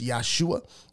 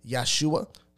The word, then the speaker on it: Yahshua: So the true names of the father, which is Yah or Yahuwah, and the true name of the Messiah, which I pronounce Yahshua. And I know some of us just have different learnings and have learned in different schools of Hebrew, which is fine. I don't think a thing like a pronunciation should drive us a wedge apart. So I know some say Yahshua, some say Yahusha, and some say Yahawashai.